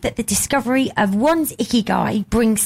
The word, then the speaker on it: Ikigai: Okay, so this is, and it's pronounced Ikigai.